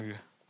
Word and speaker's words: Camus: Albert Camus, so. Alter. – Nee, Albert komm. Camus. – Meine ich ja. Sorry, Alter. – Ich sorry, ich beleidige dich jetzt sogar um Meter. Ich korrigiere dich in der Aussprache.